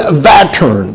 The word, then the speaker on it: Vättern: This lake is called Lake Vättern, V-A-T-T-E-R-N, is how we pronounce it in English.